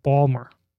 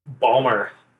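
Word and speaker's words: Baltimore: how are you enjoying Balmer? – Balmer, yeah. I have been taught the correct pronunciation by Taylor Jones, whom I think you guys are familiar with, but it's been great.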